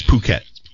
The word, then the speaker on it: Phuket: Dan, it's actually a silent H and a long O. – It is? – Yeah, it's Phuket.